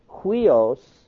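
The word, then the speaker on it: Huios (υἱός): Huios, pronounced with an H sound, huios means son.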